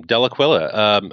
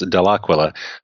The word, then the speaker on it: Dellaquila: So first of all, about my name, Dellaquila. All the Italians in the audience will know I'm mispronouncing it. In Italy, it's pronounced Dellaquila, but it actually means from the eagle.